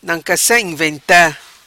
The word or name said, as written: Nancassa Inventa